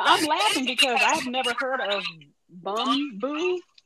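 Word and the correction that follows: Bumbu: No, it's you pronounce it just like it's spelled. Bum B U.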